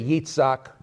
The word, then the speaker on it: Yitzhak: So when I say Avraham, yours is Abraham. And when I say Yitzhak, okay, you know it's Isaac. So I'm pronouncing it the way it was pronounced in Hebrew.